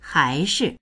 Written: hai2shi

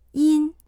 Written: yin1

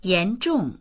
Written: yan2zhong4

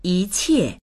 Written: yi2qie4

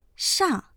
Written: shang4

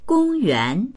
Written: gong1yuan2

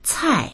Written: cai4

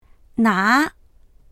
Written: na2

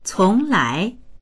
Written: cong2lai2

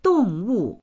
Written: dong4wu4

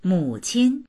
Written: mu3qin1

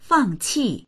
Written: fang4qi4

